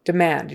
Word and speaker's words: demand: So drive demand, Demand, you've got to get the ah because that syllable is stressed.